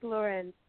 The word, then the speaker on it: Lorin: Yes, it's Lorin. But so many people say Lauren, and it's like – stupid, because now I'm starting to pronounce my name wrong. I'm like, hi, I'm Rachel Lorin. No, I'm not. Oh, crap. So, yeah. but on the record, it is Rachel Lorin, like Sophia Lorin.